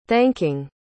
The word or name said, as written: thanking